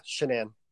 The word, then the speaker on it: Shanann: So, you had said that am I pronouncing her name right, Shanann? – Yeah, Shanann.